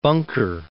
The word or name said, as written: Bunker Hill